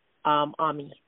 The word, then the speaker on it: AHMI: And again, it's on, everybody's like, why AMI? It's a play on the French word for friend, because that's how it started, by getting healthy with your friends.